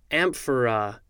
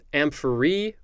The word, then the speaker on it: amphora: All right, moving on, we have amphora. Could also be pronounced amphory or amphorae. I feel like I'm singing that word. I'm terrible with words. All right, amphora, A-M-P-H-O-R-A.